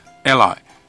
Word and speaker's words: alli: 那它的商品名非常有意思，它的商品名是 ALLI，它的发音呢跟另外一个大家熟悉的英文字完全一样 ,ally。那这个 ALLY 的意思就是盟友或是盟邦。那这个他之所以会取 ally 这个名字当做商品名，他其实是有道理的。